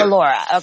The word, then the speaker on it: Mollura: Mollura.